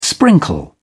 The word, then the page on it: sprinkle: British English